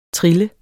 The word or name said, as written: trille